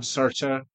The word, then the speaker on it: Sartre: Yeah, there's the old the old Sart Sart. I can't pronounce his name. – Um, Sartre.